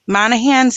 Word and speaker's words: Monahans: It's a fantastic example, because that's do you pronounce it Monahans? Monahans Sandhill State Park. Yes.